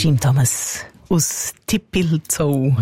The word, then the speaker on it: Tippilzou: Aber also der Urs, der wahrscheinlich dort wohnt oder einfach sehr verbandelt ist mit dem Ort, hat uns geschrieben, so wie er ihn aussprechen würde. Und er hat wirklich T-I-P-P-I-L-Z-O-U geschrieben. – Also ist Zelt, Zippi. – Dippelzau. – Ja, Dippelzau.